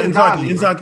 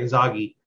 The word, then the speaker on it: Inzaghi: We'll see what is it Ingazi? I can't pronounce it. Ingazi? Ingazi, the Italian coach for Italy, Inter. – Who did they get? No, yeah, Inzaghi. – I'm sorry, Inzaghi.